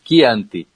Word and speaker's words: Chianti: Muita gente fala Chianti em vez de Chianti. No italiano, quando vem CH, você pronuncia que, né? Chianti, e não Chianti.